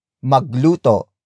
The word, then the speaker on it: magluto: For example, magluto. Magluto. Magluto. Magluto is spelled as M-A-G-L-U-T-O.